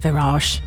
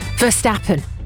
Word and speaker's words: Verstappen: How do I say it, Virage? Verstappen.